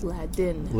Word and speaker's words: Ladin: And it's only this area where they speak Laden. It's a Romance language, so... – it's not Latin, it's Ladin. – Ladin, L-A-D-I-N, yeah.